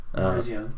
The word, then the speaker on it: Virizion: I said Virizion? Virizion? I don't know how you pronounce that. – Virizion.